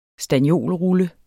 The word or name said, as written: stanniolrulle